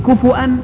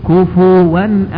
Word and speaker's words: كفؤا: فتنطق هذه الكلمة على قراءتين، كفؤا وكفوا، ولم يكن له كفوا أحد، ولم يكن له كفؤا أحد، ليس أحد يكافئه ولا يماثله ولا يشابهه ولا يقاربه، ليس كمثله شيء، وهو السميع البصير.